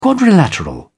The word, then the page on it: quadrilateral: British English